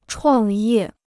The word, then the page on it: 创业: chuàng yè